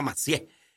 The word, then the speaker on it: Massieu: Pues cheque ahí, Claudia Ruiz Massieu, se escribe, Massieu, con doble S, Massieu, pero se pronuncia Massieu.